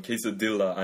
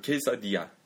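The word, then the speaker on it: quesadilla: 발음으로 하면 케사딜라 아니고 똑같이 토르틸라 아니고 토르티야. 케사디아. – 케사디아. – 네. L 두 개 나오면 항상 Y로 합니다.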